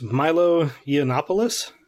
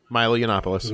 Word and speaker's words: Milo Yiannopoulos: Milo Yiannopoulos? Maybe I got it right. – You did. Milo Yiannopoulos.